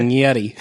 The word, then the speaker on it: Yeddi: Yeti. What? Spelt Y-E-D-D-I. – That's how they say it. – Yeti.